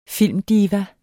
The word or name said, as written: filmdiva